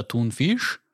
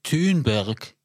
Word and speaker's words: Thunberg: Menschen, die wirklich bösartig sind, sagen Greta Thunfisch. Oder Dummberg ist auch sehr lustig. Ja, das ist es beides jedenfalls nicht. Jedenfalls Greta, also in meinem Verständnis wird Thunberg.